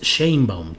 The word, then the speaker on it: Sheinbaum: Ya por aquí Fernando Gutiérrez Ramírez en el chat me dice, amigo Julio, te ves muy silvestre diciendo Chainbaum, es Chainbaum, te ves muy de San Luis.